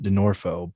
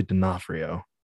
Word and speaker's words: D'Onofrio: Well, you pronounce it D'Onofrio, but it's actually D'Onofrio.